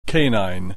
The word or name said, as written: canine